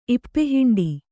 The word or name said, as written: ಇಪ್ಪೆಹಿಂಡಿ